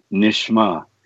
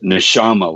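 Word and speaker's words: neshamah: Number seven, breath of life, which is the nishma. That's, people sometimes pronounce it neshama, like humans have neshama.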